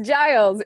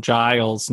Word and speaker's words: Giles: Literally the way it's written, it looks like Giles to me, not Giles. There's a J sound coming from this G that, anyway, that just looks weird.